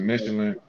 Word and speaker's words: Michelin: Oh, it's Michelin. In America, we pronounce it Michelin. In France, they pronounce it Michel.